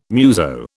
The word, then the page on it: muso: British English